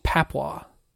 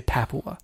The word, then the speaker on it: Papua: I want to say Papua. – It's not Papua New Guinea. That's stupid. – I think it's Papua. Papua. Papua. – Papua New Guinea.